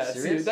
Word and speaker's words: Sirius: Yeah, Sirius? Like in Sirius Black. It's spelled exactly the same way. Nice. Sirius, yeah.